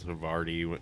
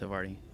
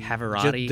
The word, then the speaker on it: Havarti: And you'd be so surprised with how many people don't know how to pronounce Havarti. – Really? – We've heard a couple dozen variations. – Okay. Havarti. Can I have the jalapeno Havarti? – Havarati.